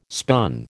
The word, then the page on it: spun: American English